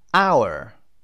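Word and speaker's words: hour: و our. اینجا H دیگه تلفظ نمیشه. Silent letter در درس‌های قبلی خوندیم این رو. H تلفظ نمیشه و این رو میخونیم.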